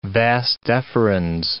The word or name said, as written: vas deferens